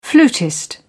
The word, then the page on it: flutist: British English